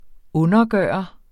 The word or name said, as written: undergører